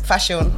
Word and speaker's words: Faseun: Oh, Faseun.